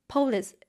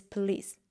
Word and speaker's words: police: Whenever I was about to speak, I often paused for like half a second to tell myself that, hey, police is wrong. The correct pronunciation is police.